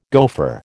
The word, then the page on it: gofer: American English